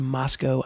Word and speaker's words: Moscow: No one cares about what you did in Moscow, Idaho.